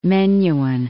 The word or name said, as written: Menuhin